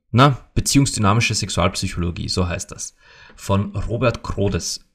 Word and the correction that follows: Kordes: äh, Kordes, Kordes, so, so spricht man das.